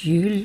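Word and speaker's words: Juul: Jesper Juhl mit zwei U geschrieben, wie wir wohl sagen würden, und Jül, wie er in Dänemark richtigerweise ausgesprochen wird, setzt auf Vertrauen und Gelassenheit statt auf Schuldsuche und Turboerziehung in der letzten Minute.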